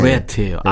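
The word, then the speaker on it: Ratt: 어쨌든 이 라트 음. LA 메탈 상징적인 밴드인데요. – 라트예요, 예. 레트예요. – R이니까. 어, 라트라고 발음이 되고 레트라고 네. 발음 되겠죠. – 네, 네, 네. – 라트. 저는 라트로 발음할게요.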